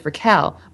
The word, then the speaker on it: Raquel: nobody can do that because rolling R's is hard, apparently. Yeah. So I go by, like, I'm okay with Raquel.